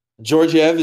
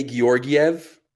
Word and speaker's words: Georgiev: Georgiev is actually playing. And I know, look, I know the announcers say Georgiev. Those are G's. I'm saying Georgiev.